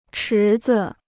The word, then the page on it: 池子: chí zi